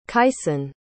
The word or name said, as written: kaison